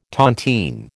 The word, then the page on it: tontine: American English